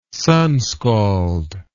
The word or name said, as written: sunscald